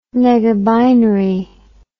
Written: negabinary

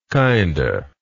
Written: kinda

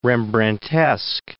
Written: Rembrandtesque

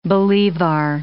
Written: bolivar

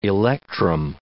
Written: electrum

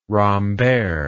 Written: rambert

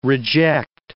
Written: reject

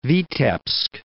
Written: Vitebsk

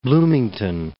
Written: Bloomington

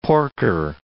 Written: porker

